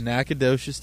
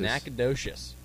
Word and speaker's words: Nacogdoches: Best of luck in, how do you pronounce it, Nacogdoches? – Nacogdoches, Texas. – Nacogdoches. It's like a sh.